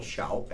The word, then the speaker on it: Shoup: My name is Daryl Shoup, S-H-O-U-P.